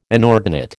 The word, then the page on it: inordinate: American English